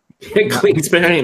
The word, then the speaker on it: Kingsbury: I think the Cardinals just badly or, you know, Kingsbury. – Kingsbury, nice.